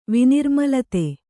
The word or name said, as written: ವಿನಿರ್ಮಲತೆ